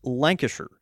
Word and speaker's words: Lancashire: It's the same with Lancashire. It's pronounced Lancashire.